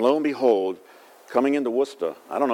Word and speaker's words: Worcester: I guess that's how they say it, Worcester.